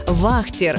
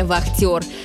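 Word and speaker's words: вахтёр: Кстати, раньше это слово звучало немного иначе – вахтер с ударением на первый слог. Но со временем произношение изменилось, и привычным стало современное вахтер.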